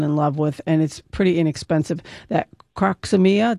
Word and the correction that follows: Crocosmia: did I say that right? Croxomia? – Crocosmia.